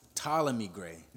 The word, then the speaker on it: Ptolemy: And it's pronounced Ptolemy Gray, not Ptolemy Gray.